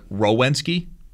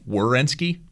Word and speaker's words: Werenski: If I say it too quickly, I'll say it like Werenski, and it's Werenski.